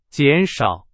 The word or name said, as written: 减少